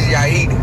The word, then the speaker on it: Yair: I have this name that white people can't pronounce. I don't know why. It's called, my name is Yair, but white people always say Yair. Like they always say something different. It's not that hard, but...